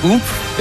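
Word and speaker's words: Oumph: it's spelled O-U-M-P-H. I guess it's like oomph is how it's pronounced or whatever.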